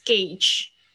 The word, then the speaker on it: gauge: 接下来是另外一个油表，这个有一点点难记哦，gas gauge。这个呢，a u 这个发发音是发 a 的声音，它是一个特殊发音，把它记起来。